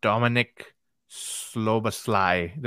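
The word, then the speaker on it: Dominik Szoboszlai: Dominic Szoboszlai. Did I say that right? – That's close enough.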